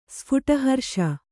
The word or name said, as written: ಸ್ಫುಟಹರ್ಷ